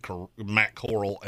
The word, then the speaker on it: corral: Could be Golden Coral for all I know. – It's not. It's the corral. It's a cowboy term. Like the gunfight at the OK Corral.